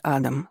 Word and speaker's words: Адам: Лера знала, что Вовку в команде звали Адам, с ударением на первую «А», как производная от их фамилии Адаманди.